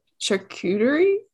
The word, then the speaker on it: charcuterie: How do I pronounce that word? Charcuterie? – Yeah.